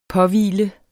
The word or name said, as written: påhvile